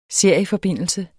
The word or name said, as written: serieforbindelse